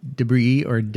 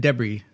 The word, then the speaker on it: debris: So the song after that is Ronnie Lane's Debris. – He says Debris. – He said Debris. I think that's the British pronunciation for the word. I would say Debris.